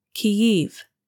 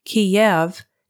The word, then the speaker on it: Kyiv: Capital, Kyiv, is the Ukrainian pronunciation. Kiev is the Russian pronunciation.